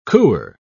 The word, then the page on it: cooer: British English